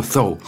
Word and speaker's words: Thoreau: Vous prononcez Thoreau en mettant sa langue sur ses dents, évidemment, en français, pour éviter qu'il y ait la moindre ambiguïté. Donc nous dirons Thoreau, un texte de 1854 qui est lu par Jean-Yves Berthelot dans Une vie, une œuvre de Françoise Estelle.